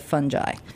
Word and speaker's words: fungi: Well, you know, if you went to if you studied Latin, you probably want to say fungi or fungi, but the mycologists say fungi.